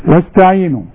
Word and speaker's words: واستعينوا: واستعينوا، الألف اللي عليها رأس الصاد ما انطقهاش، إذا كان قبلها أي كلمة أو أي حرف أقول وس، شوف زي ما تقول وسوسة كده، وس، شوف لو تتشال الواو تقول استعينوا.